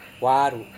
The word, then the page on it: waru: Serewen